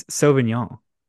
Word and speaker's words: Sauvignon: There's a whole extra syllable in there. It's Sauvignon.